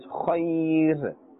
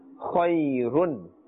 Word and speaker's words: khayr: Therefore, when we are stopping, we pronounce as khayr. Although the raw has a dhammatain, which means two dhammas, we should pronounce as run, khayrun. But since we are stopping here, we are making waqf, which means to stop. Therefore, we are not pronouncing the dhammatain, those two dhammas, and we are giving the raw a temporary sakin, a temporary jazm. This is how we are pronouncing it, khayr, right?